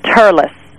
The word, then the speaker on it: Thurles: And over there you would pronounce it Thurles. – Thurles, right. Okay. – They tend to drop the H sound.